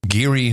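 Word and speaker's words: Gary: You came from Geary, Indiana. – Geary.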